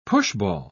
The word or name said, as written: pushball